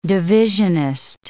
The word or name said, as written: divisionist